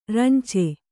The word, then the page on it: ರಂಚೆ: rance